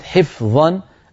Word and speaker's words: حفظا: بارك الله فيك يا أمينة، ما شاء الله تلاوتك جيدة يا بنتي، وانتبهي إلى كلمة حفظا التي نبهتك عليها. الفاء إذا جاورت حرف الظاء قد يغلب على النطق أن يقلبها إلى في، وليس في اللغة العربية حرف في.